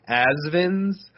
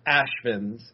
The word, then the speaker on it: Ashvins: It is spelled Asvins, so people might think it's pronounced Asvins, but there's an invisible H in there. It is pronounced Ashvins.